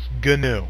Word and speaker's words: GNU: Now, GNU is typically pronounced GNU.